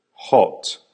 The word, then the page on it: hot: American English